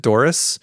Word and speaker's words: Dorys: It's spelled Dorice, like D-O-R-Y-S. But then I Googled it at some point and I was like, how to pronounce D-O-R-Y-S. And at least the internet told me that you just pronounce it Doris.